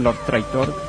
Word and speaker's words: Love Tractor: La primera va a estar a cargo de una banda que se llamó Love Tractor, o bueno, pronunciando bien, Love Tractor, que es una banda oriunda de Athens, Georgia, el lugar donde apareció R.E.M.